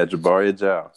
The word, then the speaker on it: Jabari Ajao: Yeah, Jabari Ajao.